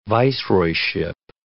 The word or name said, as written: viceroyship